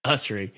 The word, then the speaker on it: Usrey: Is it Usrey or Usrey? – Usrey. You guys Usrey. Always say his name.